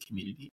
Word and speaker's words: Cxmmunity: You can name it or pronounce it Cxmmunity. It's spelled with an X, though. It's just like a unique way.